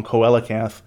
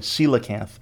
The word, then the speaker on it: coelacanth: In the last episode, I was pronouncing it wrong, coelacanth. I was doing the classical Latin pronunciation, coelacanth, apparently, coelacanth.